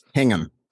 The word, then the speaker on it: Hingham: Yes, Hingham.